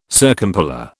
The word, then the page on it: circumpolar: British English